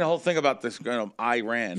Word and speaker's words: Iran: In the game against Iran. Yeah. – Right. Saying it right? I'm not one insulting nobody. Iran? – Iran, that's Iran. Correct.